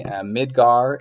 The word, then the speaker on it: Midgar: As far as I've been able to tell, and as far as my memory serves me, Midgar is the correct pronunciation of the town from, or the city really, from Final Fantasy VII.